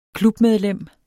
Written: klubmedlem

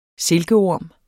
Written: silkeorm

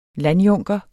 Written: landjunker

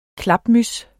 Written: klapmyds

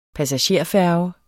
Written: passagerfærge

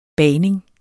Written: bagning

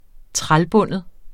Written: trælbinde

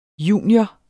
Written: junior

